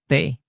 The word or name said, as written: t